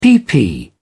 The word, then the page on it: pp: British English